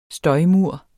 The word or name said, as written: støjmur